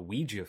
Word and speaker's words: Ouija: Ouija, is that how you pronounce it? The Ouija board? – Ouija, movie? Yeah, yeah.